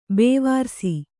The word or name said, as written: ಬೇವಾರ್ಸಿ